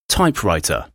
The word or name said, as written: typewriter